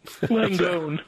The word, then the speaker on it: Langone: Langone.